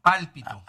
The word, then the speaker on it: pálpito: Yo tengo ese palpito que se siente. ¿Cómo se llama? No, lo dije mal. Perdón. No. El pálpito.